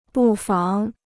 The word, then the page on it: 不妨: bù fáng